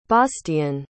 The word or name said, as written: baastian